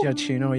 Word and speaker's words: Giacchino: Giacchino, yeah.